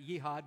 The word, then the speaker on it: jihad: Some jihadists, do you know the word jihad, J-I-H-A-D? Some pronounce it jihad, but jihadist, it's known as holy war.